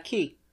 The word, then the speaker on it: quay: How would you pronounce this word? Q-U-A-Y. – Quay? – Do you know? – Key. – Yep. Key. – Okay. – See, it's a British word.